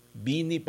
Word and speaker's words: Bini: So, Bini ha? Bini! Bakong Benny!